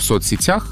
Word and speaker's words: соцсетях: Правильно в соцсетях. Во множественном числе ударение переходит на окончание.